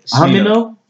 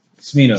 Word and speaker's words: Smino: What's his name? Amino? – Smino.